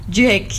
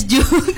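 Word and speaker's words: JVKE: Because before that we pronounce his name as Juke. Actually, it is Jake ya teman Pro 2. Ya, not Juke ya.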